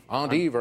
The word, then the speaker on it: endive: You can pronounce these leaves a la European, to rhyme with long sleeve, or the regular American way. Carter. – What is endive? – Endive or endive.